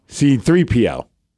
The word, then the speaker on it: C-3PO: I always want to call him 3CPPO. – 3CPPO. – Or something. Or is it, that's not how you say his name. Is it C3P? I don't know. – C3PO. – C3PO.